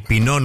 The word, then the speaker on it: Pinot: El Pinot Noir no viene del pino. – No. – Aunque suene. Claro. Sí, porque ese pino termina con una T final. – Claro. – Claro. – Que no se pronuncia.